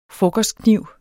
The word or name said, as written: frokostkniv